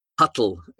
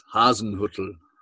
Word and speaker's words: Hasenhüttl: it's not Huttle, it's Hasenhüttl.